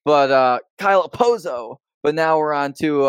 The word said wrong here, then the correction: Okposo: But have we said nice things about Kyle Okposo? – We have 2 weeks ago, almost positive.